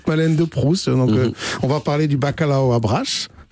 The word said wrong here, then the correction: bacalhau: C'est bien prononcé, là, ça c'est le L est mouillé, c'est liao. – Bacalhau.